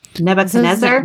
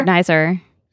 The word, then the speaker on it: Nebuchadnezzar: Nebuchadnezzar. – Nebuchadnezzar. Nebuchadnezzar.